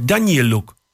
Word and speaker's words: Danieluk: Ik zei, maar Dani Luk, is dat een Poolse naam? Ja, het is een beetje. Ik zei, maar je spreekt het niet zo uit. Ik zei, maar hoe spreek je het dan uit? Ja, Danieluk.